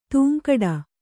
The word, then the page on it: ಧಿಂಕಿಡು: dhiŋkiḍu